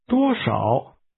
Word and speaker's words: 多少: This expression is pronounced with a high level tone, followed by a falling, rising tone. Ask, how much? – 多少? – How much money?